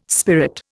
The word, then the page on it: spirit: Indian